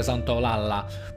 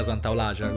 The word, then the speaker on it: Santaolalla: Per noi italiani si dice Santaolalla, però in realtà è argentino si pronuncia Santaolagia, così vi do questa chicchetta.